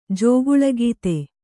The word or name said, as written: ಜಗಳಗಾತಿ